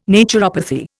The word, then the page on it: naturopathy: Indian